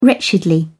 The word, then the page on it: wretchedly: British English